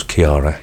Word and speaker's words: Chiara: C-H-I-A-R-A. – Yeah, I'd say it was Kiara, yeah.